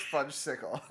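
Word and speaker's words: Fudgsicle: You said there'd be fudgicles, Bart. – Where are the fudgicles? – First of all, it's pronounced fudge-sickle.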